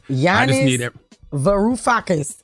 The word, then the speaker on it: Yanis Varoufakis: Yanis Varoufakis.